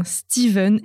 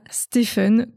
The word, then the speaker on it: Steven: Alors après quelques petites recherches, eh bien, on dit bien Steven et non pas Stephen, comme on serait tenté de le dire en bon français. En deux mots, PH se prononce V, et avec l'évolution de la langue, pour que les gens arrêtent de se tromper, on a fini par changer l'orthographe en mettant un V.